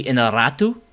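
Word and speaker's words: Iñárritu: Inarritu.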